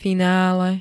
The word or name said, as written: finále